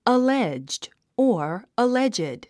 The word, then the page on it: alleged: əlédʒd; アレェッジド